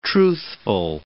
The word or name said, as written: truthful